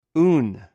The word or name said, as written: oun